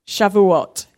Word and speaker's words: Shavuot: Shavuot, yeah, which is Pentecost.